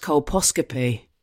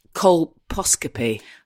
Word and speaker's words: colposcopy: And I think it's pronounced colposcopy. – Oh, fuck. Colposcopy. Yeah. You're right. How do you say it? – Colposcopy. – Well, see, it just sounds like cold popsicle.